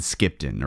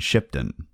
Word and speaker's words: Skipton: So Keswick and Skipton are examples of this. In their Anglo-Saxon days, they would have been Cheswick and Shipton.